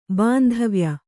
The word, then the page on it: ಬಾಂಧವ್ಯ: bāndhavya